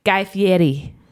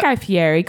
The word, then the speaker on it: Guy Fieri: The interviewer pronounced Guy Fieri's name as Guy Fieri. Like Guy Fieri, like very Italian. And Paul Rudd was like, why are you pronouncing his name like that? It's Guy Fieri. Calm down. And he was like, no, that's how he pronounces his name.